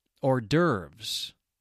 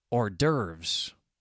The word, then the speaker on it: hors d'oeuvres: And this one is just for you, another word with a silent H beginning that comes right from a French term. Hors d'oeuvres. Renaud probably won't even recognize my pronunciation, but that is a common pronunciation in American English.